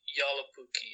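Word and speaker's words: Joulupukki: but Julupuki, right? Julupuki, which means... – Yalupuki. Yalapuki, yeah. Yalapuki? Like the J would probably be more of a Y sound, but yeah.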